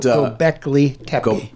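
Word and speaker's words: Göbekli Tepe: No, doing research for Gobekli Tepe. – It's a difficult pronunciation, but Gobekli Tepe.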